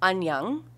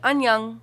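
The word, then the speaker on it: annyeong (안녕): So this is spoken in North Korea and South Korea, and the casual way of saying hello is annyeong, and like annyeong, like you would say it just like that.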